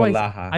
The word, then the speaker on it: Polaha: And then earlier I was like, kept saying Pohala, and you're like, it's Palaha, you idiot.